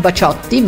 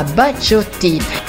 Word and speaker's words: Baciotti: Fatto sta che bisogna pronunciarlo alla francese, quindi non Baciotti ma Baciotti, che nel 1977 ottenne un buon successo anche in Italia con la canzone Blackjack.